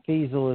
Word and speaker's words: Feazel: If you just type that title into Amazon or type Huckabee's name or type Huckabee and my name, Feazel is spelled F like in football, E-A-Z-E-L.